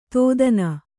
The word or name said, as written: ತೋದನ